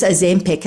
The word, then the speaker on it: Ozempic: Ozempic, and I hope I'm pronouncing this yeah, you are.